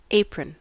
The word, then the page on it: apron: American English